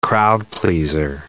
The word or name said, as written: crowd-pleaser